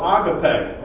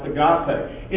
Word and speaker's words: agape: That word love is the word we know, agape, or sometimes pronounced agape.